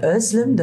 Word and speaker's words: Özlem: im Türkischen würde man Özlem, das Z, wie ein weiches S.